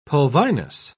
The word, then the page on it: pulvinus: British English